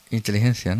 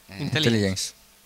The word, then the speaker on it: Intelligence: Alguien que sepa inglés bien, lo pronuncie bien, diga lo que significa Search Extraterrestrial Inteligencia, ¿no? – Intelligence.